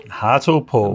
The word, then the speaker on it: Hartlepool: And he's from Hartlepool. – Hartlepool. – I've never heard of that place. Is that how you pronounce it? – Yeah.